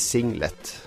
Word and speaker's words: singlet: Hvorfor skal det hete singlet? – Det er sånn det uttales. Singlet. Det er jo veldig fransk.